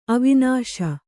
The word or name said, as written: ಅವನೀಶ